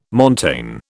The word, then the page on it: montane: British English